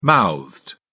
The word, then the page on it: mouthed: British English